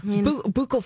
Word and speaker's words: buccal: Buccal fat removal. – Yeah. They do that. Fat. – Or Yeah. Yeah. – I'm saying it right, aren't I? – Yeah, you are.